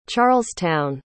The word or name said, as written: charlestown